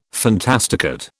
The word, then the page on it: fantasticate: British English